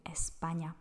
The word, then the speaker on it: España: ale Španělé mají Espaňa, protože tam máme SP.